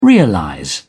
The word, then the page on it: realize: British English